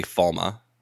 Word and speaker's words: Falme: One thing about this whole Tommen's head thing, though, I hate the pronunciation of it's Falme. They're calling it Falme. – I think in the books they say Falma.